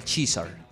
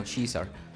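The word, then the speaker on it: Caesar: canción del señor Daniel Kaiser, que... ¿Cómo es? Daniel Chizar. Es, como, ese es, en inglés se pronuncia como Chizar.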